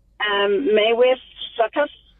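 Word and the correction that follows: Maewyn Succat: Maywin Suckat. It's spelled Maewyn, second word, Suckat.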